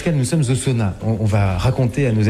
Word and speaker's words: sauna: En finnois, bien sûr, on appelle ça un sauna. – On le sait maintenant, on prononce toutes les lettres.